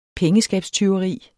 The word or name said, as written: pengeskabstyveri